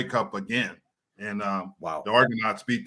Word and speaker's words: Argonauts: Argonauts. Argonauts.